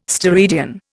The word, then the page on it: steradian: Indian